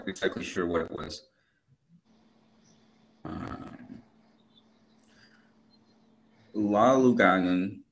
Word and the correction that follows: Laulauga: I'm mispronouncing this. – Who? – It's Laulauga. That's the first name. Last name is Tausaga.